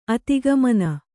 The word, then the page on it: ಅಧೋಗಮನೆ: kannada